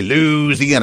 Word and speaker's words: Louisiana: You have to say Louisiana because that's how they say it, right? – Yeah, I think so.